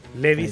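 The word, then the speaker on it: Levi's: Levi se, se, pronuncia Levi.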